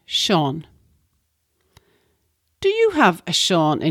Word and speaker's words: Seán: And we spell Seán S-E-A-father, that's a line over the A-N, S-E-A-father-N, Seán.